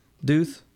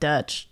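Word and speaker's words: Deutch: Yeah, Howard Duth. – Is that how you say it? Oh, I was saying like Deutsch.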